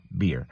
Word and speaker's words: bier: It's the same sound as beer when you drink, but beer that people drink, you shouldn't drink. I don't drink it at all, but it's B-E-E-R, beer. And this one is B-I-E-R, and the same pronunciation, beer. And what is this beer, B-I-E-R?